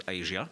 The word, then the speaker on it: Eja: soos Asia, maar die spelling is E-J-A, maar die uitspraak is Asia.